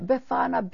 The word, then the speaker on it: Befana: Yeah, Befana, B-E-F-A-N-A, Befana.